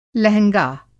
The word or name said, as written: लहँगा